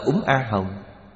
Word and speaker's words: Úng A Hồng: Tại Trung Quốc có khi viết chữ Phạn, có khi viết chữ Tây Tạng, có khi viết chữ Hán, âm đều giống nhau, đều đọc là Úng A Hồng.